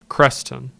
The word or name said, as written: Creston